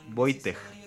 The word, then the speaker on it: Wojtek: Un soldado reparó en que los Cesno aún no tenía nombre y decidieron bautizarle con un nombre típicamente polaco, Wojtek, pronunciado, ah, pronunciado Boy, Wojtek.